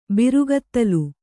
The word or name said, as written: ಬಿರುಗೂದಲು